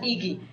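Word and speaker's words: Igy: It's I-G-Y. Pronounced Iggy. Iggy. They have the word for harmony, you know, D-E-I.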